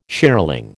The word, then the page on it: shearling: American English